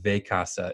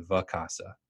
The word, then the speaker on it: Vacasa: I mispronounced the name of Bob's company in this podcast a few times. I said Vacasa. It's actually Vacasa. So I said it incorrectly.